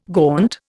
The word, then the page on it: gaunt: Indian